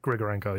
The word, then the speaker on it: Grigorenko: How do you say his name? Grigorenko? – Grigorenko, yeah.